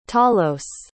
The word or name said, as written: talos